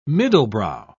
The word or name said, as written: middlebrow